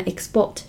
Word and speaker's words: export: 那么 export 是它作为动词时的发音。